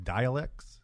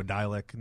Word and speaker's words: Dalek: I didn't realize that's how you pronounce the word. I guess I always assumed they were called like dialects. – Oh, right. Yeah. – And now Dalek, now it makes sense because it kind of.